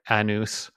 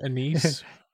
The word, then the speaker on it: anise: Anus, when you were saying anise. – It's anise. – Star anus. – Yeah, what was I saying? I was saying I call it something else. Anus. That's what I was anise. Calling it. – Yeah. – Anus. – When it's supposed to be anise. Which I think is how you pronounce it.